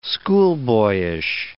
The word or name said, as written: schoolboyish